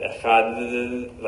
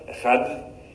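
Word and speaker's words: Echad (אחד): The way that the Dalet used to be pronounced in Hebrew was much more like the Yemenites say today, not like, right? They said Echad, like more like a th type of sound.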